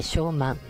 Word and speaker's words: 小満: 小さい満足と書いて小満でございますね、はい、この時期に体調を崩しやすいなどと言われておりますので、ぜひ気をつけていただきたいと思います。毎年 5 月の半ば頃にこの小満なんですって、